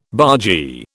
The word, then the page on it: bargee: British English